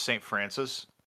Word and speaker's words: St. Francois: It's spelled St. Francois, but it's pronounced St. Francis.